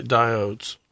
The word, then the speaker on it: diodes: Not deodinase, right? Diodes. – Diodes, yes. Not deodinase or diodines. – Okay. – If you want to pronounce it incorrectly. – Like we did for years.